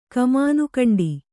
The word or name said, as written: ಕಮಾನುಕಂಡಿ